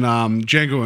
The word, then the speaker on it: Django: He's like, Django. And then Fox is like, the D is silent.